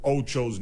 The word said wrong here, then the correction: Acho: name wrong. Apparently, it's Emmanuel Acho.